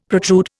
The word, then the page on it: protrude: Indian